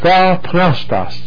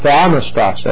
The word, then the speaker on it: theopneustos: That word given by inspiration of God, all Scripture is given by inspiration of God, or God breathed, theopneustos, or theonestos, as some pronounce it.